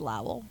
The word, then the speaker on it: Lowell: But what if it is Lowell? – I'm just pronouncing it Lowell from now on, though.